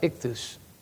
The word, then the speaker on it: ichthus: That I-X-O-Y-E is pronounced ichthus.